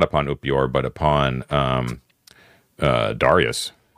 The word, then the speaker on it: Upior: U-P-I-O-R. Upior. – Okay. So you're going to be actually like still in Upior is the correct pronunciation. Sorry.